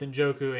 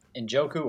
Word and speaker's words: Njoku: And then, yeah, you said it right with both. I mean, Njoku had another pretty solid game, both Njoku and Amari Cooper, the two main pass catchers.